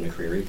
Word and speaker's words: McCreery: Again, David McCreary, pronounced correctly.